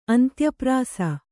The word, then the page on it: ಅಂತ್ಯಪ್ರಾಸ: kannada